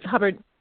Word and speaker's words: Hubbard: I think it's Hibbert. I think I said her name wrong. Hubbard? Hibbert? Help me out. – There. Hubbard. Hubbard. – Okay. Julie Hubbard. – Hub. A hub. Like a hub cat.